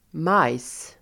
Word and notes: majs: [maj:s]

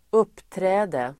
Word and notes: uppträde: [²'up:trä:de]

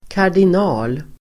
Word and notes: kardinal: [kar_din'a:l]

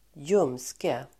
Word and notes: ljumske: [²j'um:ske]

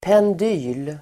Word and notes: pendyl: pend'y:l (el. pang-)

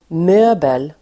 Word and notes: möbel: [m'ö:bel]

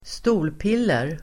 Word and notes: stolpiller: [²st'o:lpil:er]

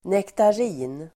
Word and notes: nektarin: nektar'i:n

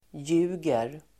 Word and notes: ljuger: [j'u:ger]

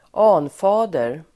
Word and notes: anfader: [²'an:fa:der]

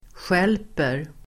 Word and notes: stjälper: [sj'el:per]

